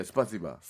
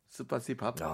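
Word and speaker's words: 스파시바 (спасибо): 스파시바. – 스파시바. 스파시바. – 스파시밥.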